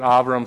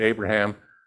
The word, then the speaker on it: Avram: Sometimes I pronounce it Avram, sometimes I pronounce it Abraham. forgive me for that, but in Hebrew, the B, the bravo, sounds like our V for Victor.